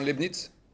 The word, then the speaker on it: Leibniz: L-E-I-B. C'est comme Leibniz. Ah bon? Vous le prononcez comment? Leibniz? – Oui. – Ah bon, Leibniz. Wow.